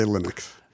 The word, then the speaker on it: Linux: How do you say it? – We say Linux.